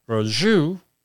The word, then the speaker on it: Brosseau: It is from N95 respirators and surgical masks, and blog post from the NIOSH Science Blog, October 14, 2009, by Lisa Brasu. I believe that's how you pronounce it, Brozu, B-R-O-S-S-E-A-U, and Roland Berrien.